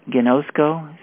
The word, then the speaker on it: ginosko: And it is ginosko, spelled G-I-N-O-S-K-O. And the pronunciation G-H-I-N-O-C-E with the emphasis. -K-O, and it also says long, quote, O, quote, sound. It says long O sound, so gnosko.